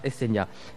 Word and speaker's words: Hecenia: Alors, Essenia, s'écrit comment ? H-E-C-E-N-I-A. Voilà, Essenia.